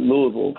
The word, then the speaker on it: Louisville: I like how you pronounce it Louisville because nobody else would unless you live there, right? – you, you really Louisville.